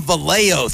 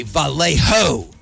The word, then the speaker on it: Vallejo: They literally wrote it out saying, and people in Vallejo say Vallejo.